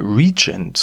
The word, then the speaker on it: Regent: Nach kurzer Verschnaufspause geht's dann direkt weiter von der Eastside Gallery mit der U-Bahn zur französischen Straße und zwar ins Hotel Regent, so spricht man es nämlich richtig aus, wo mich mein Interviewgast schon erwartet.